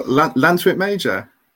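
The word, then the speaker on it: Llantwit Major: Well, Lantwit Major. – I see. I would have gone with a K, but yeah.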